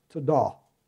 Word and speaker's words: todah: T-O-D-A-B, pronounced todah, I believe.